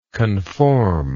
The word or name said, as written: conformingly